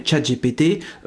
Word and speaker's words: ChatGPT: Oui, exceptionnellement, on va privilégier la prononciation anglaise pour éviter la tentation de rajouter à chaque fois après ChatGPT euh, dans l'eau, ça fait des bulles, c'est rigolo.